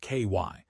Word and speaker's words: Kawhi: There is the unresolved investigation into Kawhi Leonard, pronounced KY,